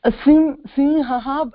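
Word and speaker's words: Simhaha (सिंहः): But except we have to, like, when you said Eshah Simha, we have to pronounce the visarga also. – Okay. Eshah Simha, Singha. Is that how you say? – Simhaha, it becomes very difficult.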